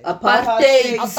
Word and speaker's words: apartheid: How do you pronounce apartheid? – Apartheid.